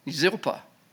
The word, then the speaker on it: Zilpah: And Laban gave unto his daughter Leah Zilpah, how do you say that? Zilpah.